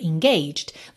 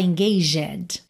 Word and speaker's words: engaged: E atenção à pronúncia, olha, engaged. Você não fala engaged. Esse último é completamente mudo.